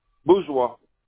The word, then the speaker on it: Bourgeois: Bourgeois. – Okay, can you spell that? – B-O-U-R-G-E-O-I-S.